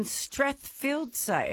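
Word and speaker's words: Strathfieldsaye: Leanne is in Strathfield, say.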